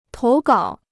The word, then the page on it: 投稿: tóu gǎo